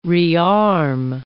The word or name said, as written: rearm